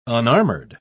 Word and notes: unarmored: British English

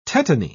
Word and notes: tetany: British English

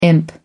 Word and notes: imp: American English

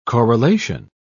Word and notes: corelation: British English

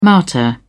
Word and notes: martyr: British English

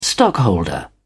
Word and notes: stockholder: British English

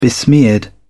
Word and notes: besmeared: British English

